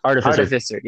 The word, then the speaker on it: Artificer: Arts of Fire. I don't know if I'm saying that right. – Artificer. – Yeah, there we go. Artificer and Mystic.